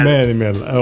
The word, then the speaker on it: Manimal: Eh, Ok, speriamo la pronuncia dico bene, Manimal. – Manimal, ok.